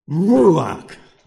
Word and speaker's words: ruach: Just a quick aside, the word spirit in the Old Testament, some of you can remember, it's the word ruach. But how it's pronounced, well, not so much pronounced, how it's to be spoken is like this, ruach.